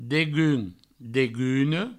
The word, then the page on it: degun, deguna: Provençal; degun, deguno